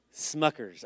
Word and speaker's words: Smuckers: I just passed Schmuckers, okay? Is that how you say it, Schmuckers? Smuckers. I just passed Smuckers in Orwellville.